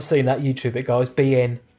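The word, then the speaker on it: Biehn: Okay, so it's got Brittany Murphy as Jodie Markin, Jay Moore as Leonard Marston, Gabriel Mann as Kenny Ascot, Michael Bain. Is that how you say his name? Is it Bain? – Bien, Michael Bien. – Oh, Bien.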